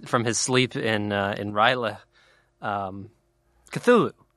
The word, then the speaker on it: Cthulhu: I mean, is that how you pronounce it? – That's how I've always said it. – Okay. – You say like Cthulhu or something. – Wow. – Which sounds like a labored sneeze.